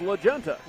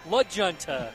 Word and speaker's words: La Junta: It's actually pronounced La Junta. – La Junta.